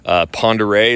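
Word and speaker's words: Pend Oreille: Ponderé is our lake, and it's spelled P-E-N-D-O apostrophe O-R-I-E-L-L-E.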